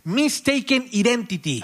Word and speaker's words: Mistaken Identity: Entonces se aplicó esta famosa regla, a ver si lo digo bien, Mistaken Identity. – Ah, perfecto. No, tu inglés es fantástico.